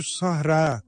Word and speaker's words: Sahra: Sevgili kardeşlerim, bu kelimeyi yanlış telaffuz ediyorlar. Sahra, Kutbetü Sahra diyorlar. Sahra değil, Sahra. Ra çıkmıyor. Ra'nın önünde yuvarlak ta var.